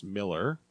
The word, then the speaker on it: Millar: This was written by Mark Miller, even though it's spelled M-I-L-L-A-R. I believe it's pronounced Miller.